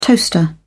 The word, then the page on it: toaster: British English